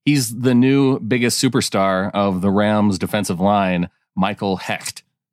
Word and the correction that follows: Hoecht: Yeah, and I found out it's pronounced Hoyt. – Hoyt. – Which I don't know. H-O-E-C-H-T makes a Y sound, but go on. However, from what I understand, it's Hoyt.